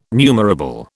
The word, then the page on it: numerable: British English